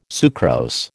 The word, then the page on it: sucrose: American English